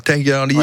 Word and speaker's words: Tigerli: Ça se prononce comme ça, T-I-G-E-R-L-I, donc Tigerly.